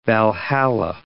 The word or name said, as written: Valhalla